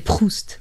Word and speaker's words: Proust: Uh, aliás, agora pronunciei mal, pronunciei a americana, é Proust.